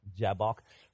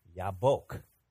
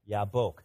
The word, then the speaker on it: Yabok: Yeah, that's how we say it, Jabbok. But in Hebrew, here's how they say it. They say Yabok. Okay, let's say that. 1, 2, 3. Yabok.